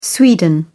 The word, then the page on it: sweden: British English